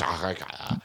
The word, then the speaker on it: Oaxaca: But, I mean, it's just not Oaxaca. – Am I saying it wrong? – No, but they're saying it wrong. – They're saying it wrong. – It's Oaxaca. Oaxaca, whatever it is.